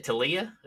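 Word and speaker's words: Taulia: How do you say it? Tulea? Is that right? – Tulea. – Tulea. Mm-hmm. Tua's little brother. Yeah. That's the way I'll pronounce it.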